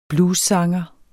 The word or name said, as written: bluessanger